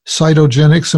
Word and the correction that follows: cytogenetics: Am I pronouncing that correctly? – Yeah, it's cytogenetics. C-Y-T-O, which yeah, just means cytogenetics.